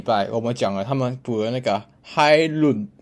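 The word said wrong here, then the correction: Højlund: 我我去查他怎么念，嗯、就是 Hoy 伦，哎、欸、 Hoy 伦，对 Hoy 伦，然后他还说他的 D 要把那个舌头就是夹在他的牙齿两两侧，不不两侧，就把牙齿夹起来，嗯、然后夹舌头才念出那个 D。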